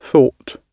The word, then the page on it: thought: British English